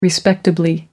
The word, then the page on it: respectably: American English